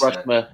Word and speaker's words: Rushmer: So, wow, Alex Rushler, I'm looking forward to getting in touch with him and getting him on. – Yeah. – Sorry, can you spell it then? – R-U-S-H-M-E-R, Alex Rushmer.